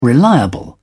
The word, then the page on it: reliable: British English